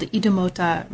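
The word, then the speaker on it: Idumota: And the major case study here is a place called Idemota Market. I don't know if I'm pronouncing anything. – You are. You are. – So Idemota Market, can you introduce that as a space for us in terms of your experience with it during your fieldwork? – Idemota Market is located on Lagos Island.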